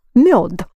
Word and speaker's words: мёд: Same year, but with two dots, pronounced ё, мёд.